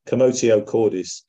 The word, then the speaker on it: commotio cordis: I'm going to go with the Latin pronunciation, commotio cordis.